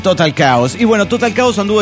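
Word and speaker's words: Total Chaos: En realidad es Total Caos, pronúnciese así.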